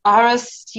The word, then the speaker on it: Aarhus: And you are from the Danish Epilepsy Centre and at Aarhus University Hospital. Did I pronounce that correct? – Correct. Yes, absolutely.